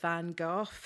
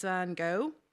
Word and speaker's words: Van Gogh: I say, here we pronounce it Van Gogh. I'm quick to side with my fellow Europeans. It's only 6 months since the Brexit vote. My friend is an American. He insists it's Van Gogh, and I concede to go with Van Gogh.